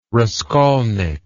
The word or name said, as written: raskolnik